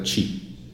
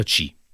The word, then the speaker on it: Qi: Sì, tra l'altro si scrive QI, però si pronuncia C. Non si sa perché, non so se tu lo sai. – Si pronuncia C perché è cinese, ed è una parola cinese che vuol dire flusso di energia